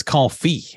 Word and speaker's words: confit: It's confit. It's French.